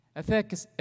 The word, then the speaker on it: efficacy: Thirdly, it is also greater in efficacy. All right, is that how you pronounce that? All right? Efficacy. Thank you for born and raised here. Amen? With American tongue, thank you for this.